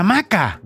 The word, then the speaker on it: hamaca: ¿Día de la Hamaca? – De la Hamaca. – Bueno, hamaca le decís tú, pero yo le digo hamaca porque la H no se pronuncia.